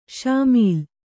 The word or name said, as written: ಷಾಮೀಲು